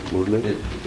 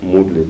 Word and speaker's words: Murali: Murali? – It's spelled M-U-R-A-L-I, Murali, but it's pronounced Muralidhar. – То есть пишется как Мурали, но произносится как Мурли, то есть Мурли Дар, это Кришна, который играет на флейте Мурали.